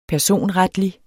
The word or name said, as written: personretlig